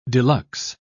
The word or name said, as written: deluxe